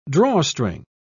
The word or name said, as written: drawstring